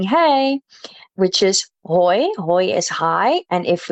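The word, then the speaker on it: hooi: And that sounds a lot like hey as well, which is hoi, hoi. So het konijn eet hoi in zijn kooi. So think cage, same sound with a K. But then it rhymes with hoi, hoi and kooi.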